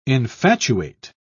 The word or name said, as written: infatuate